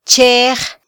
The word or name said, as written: chej